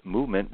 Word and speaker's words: MVMT: MVMT Watches, pronounced Movement, was founded on the belief that style shouldn't break the bank.